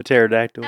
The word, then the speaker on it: pterodactyl: A pterodactyl. – Oh. Wait, I don't get it. – So when you spell pterodactyl, it's spelled P-T-E-R-O. The P is silent, so.